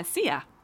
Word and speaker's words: Szia: So what is it? See ya? – See ya, yeah. – Do you have to say that with a chandelier on your head? – No, I don't think so. It's spelled S-Z-I-A, by the way.